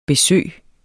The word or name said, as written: besøg